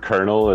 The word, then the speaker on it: colonel: It's like the word kernel. It's like C-O-L-O-N-E-L.